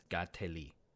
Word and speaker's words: Kateri: Although, so I looked this up, apparently the actual Mohawk pronunciation is Gateli. I do not understand this language. So, again, I am going to use the more common phonetic pronunciation.